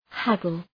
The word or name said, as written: haggle